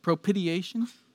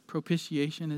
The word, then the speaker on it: propitiation: Or as a former pastor once pronounced it, propitiation. It's not the best way to say it. Propitiation is best.